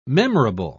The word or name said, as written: memorable